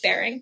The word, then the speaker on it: Bering: Of course, the bear- bearing. Is that even how you say it? – Bearing, yeah. – Sure. Bering Sea.